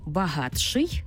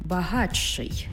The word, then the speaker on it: багатший: «Ти» і «ши» утворюється «чи-ши». Пишемо «багатший», але вимовляємо «багатший». «Ти» і «жи» утворюється «джи».